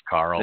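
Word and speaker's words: Qarl: I don't know if you guys have the Greyjoy hero box 1 on there, but it looks like I see Quarrel. I think that's how you say it's Carl. – The name. Carl? Okay.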